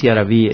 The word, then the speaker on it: yaraví: porque si decimos yaraví estamos acentuando la última sílaba, yaraví, poniéndole incluso tilde. Eso ya es absolutamente castellanizado porque en el quichua no existen palabras agudas.